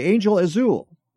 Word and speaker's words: Angel Azul: Marcy had a film 4 years ago called, I want to make sure I pronounce this correctly, Angel Azul. Is that how you pronounce? – Okay. That's right. Yeah.